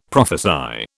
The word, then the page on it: prophesy: British English